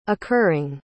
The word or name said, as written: occurring